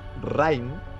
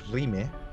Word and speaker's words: Rime: Vengo a recomendar un juego que se llama Rime. Eh, Rime, se escribe R-I-M-E.